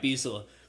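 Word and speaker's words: Beistle: Is it Beasel or Beasley? Oh. Beasel? – I think it's Beasel. – All right, Beasel.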